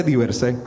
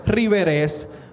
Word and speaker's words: Diversé: Hablo desde cómo se escribe, que se escribe D-I-V-E-R-S-E, sí, gané el spelling bien en mi escuela. Y aparte de eso, ¿cómo se dice? Se dice Diversé, pero la gente me dice Riverés. Me dice víveres, me dicen divers, pero el más que me gusta de todos es el diverse.